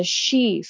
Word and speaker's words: Sidhe: Yeah, so in Fae mythology and legend, the they're called the She Fae, but it's spelled SIDHE, and it translates to the hill fairies.